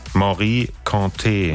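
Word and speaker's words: Mory Kanté: Wie wird's ausgesprochen? – Morikante. – Ach so, guck mal. Das war also falsch.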